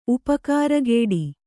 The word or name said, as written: ಉಪಕಾರಗೇಡಿ